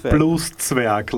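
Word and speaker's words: Blueszwerg: Christian oh, blue It's Blues Zwerg, like dwarf, uh, blues dwarf, but only in size, not in.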